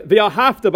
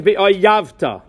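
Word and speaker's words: V'ahavta (ואהבת): or the word V'ahavta by saying V'ayavta, or Ahava, Ayava, Ahavta, Ayavta.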